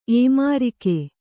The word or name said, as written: ಏಮಾರಿಕೆ